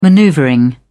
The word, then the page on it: maneuvering: British English